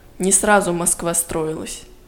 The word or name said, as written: не сразу Москва строилась